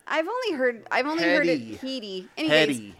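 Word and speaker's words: Hedē: It's H. Well, how would you pronounce H E D E? – A what? – Heady. – H E D E. – Yeah, that's how they're saying to pronounce it. – That would be Heed. – That's Heed. – Look, actually, Heed H E E D. – H E D E with a line over the second E. – Oh. – Yeah. Yeah. Okay. So the pronunciation is Heady.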